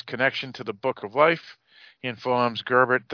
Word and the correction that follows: Joubert: that, or how do you say his name? Not Gerbert, he informs Joubert that one missing page was entrusted to each of the three supernatural species.